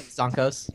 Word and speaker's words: Zonko's: The Zonko's gift shop, if I pronounced that right. – Yep, Zonko's.